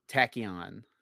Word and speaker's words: Tachyon: Now what is the ability, how do you pronounce the ability's name? – Tachyon. – Okay, that's what I thought. I wasn't sure if I was getting it.